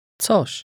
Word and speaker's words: Coś: Coś. Coś. Coś. – Notice the ts sound at the beginning.